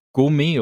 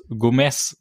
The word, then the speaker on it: Gomez: Und zwar äh, der große Gegenspieler äh, Juan wird in dieser Folge von den drei Fragezeichen und auch von den meisten anderen Figuren die ganze Zeit Gourmet oder Gourmet ausgesprochen. – Mm, also es klingt eigentlich schon mhm. fast französisch. Ja. Und eigentlich, also auch so wie er geschrieben würde, müsste es Gomez sein, also mit Z am Ende und dieses Z wird aber wie ein hartes S ausgesprochen.